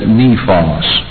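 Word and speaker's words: nepios: In the Greek, it's N-E-P-I-O-S. Now, I guess you pronounce that nephos. Now, you have another word, which is called H-U-I-O-S, or weos.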